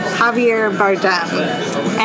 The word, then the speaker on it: Javier Bardem: Javier Bardem.